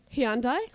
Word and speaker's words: Hyundai: Syllables in Hyundai, not high-end Hyundai. It sounds like you are out of breath. That you're having, you're hyperventilating during the season. Say it again. – Hyundai.